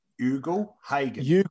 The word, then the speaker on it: Ugle-Hagan: The sort of discussion around the number one pick, Jamara, and I've got to get his name right. – Ugle-Hagan. – Is that correct? – It is correct.